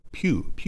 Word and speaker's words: Pugh: Pugh. By the way, our listener Tiger Ray. I've been mispronouncing her name forever. So Pew, Elizabeth Pew.